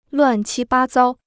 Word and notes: 乱七八糟: luàn qī bā zāo

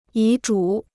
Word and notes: 遗嘱: yí zhǔ

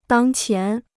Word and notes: 当前: dāng qián

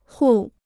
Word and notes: 户: hù

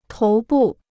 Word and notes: 头部: tóu bù

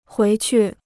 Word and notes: 回去: huí qu

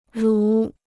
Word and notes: 如: rú

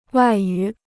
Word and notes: 外语: wài yǔ

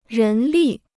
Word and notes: 人力: rén lì

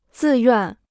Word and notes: 自愿: zì yuàn

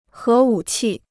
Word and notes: 核武器: hé wǔ qì

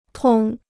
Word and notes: 通: tòng

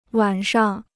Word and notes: 晚上: wǎn shang

